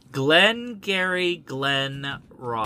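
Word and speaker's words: Glengarry Glen Ross: So it would be weird if it doesn't show up for Jack Lemmon, so I'm going to say Glenn Glarigan Ross. – You have to pronounce it correctly. – I can never pronounce it correctly. – Well, if you don't pronounce it correctly, you don't get credit for it. – I am a mush mouth. Glenn Gary Glenn Ross. – Perfect score.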